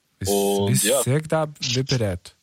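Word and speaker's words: Всегда вперёд: Всегда вперед. – Wie bitte? – Was? Wie? Sag doch bitte mal auf Russisch. – Всегда. Immer. Всегда. Вперед. Vorwärts. – Okay, da habe ich es komplett falsch ausgesprochen gerade.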